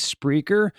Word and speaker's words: Spreaker: Okay, I, I pulled this little little uh, article here, and they spelled it speaker and it's not Speaker. The company's name is Spreaker.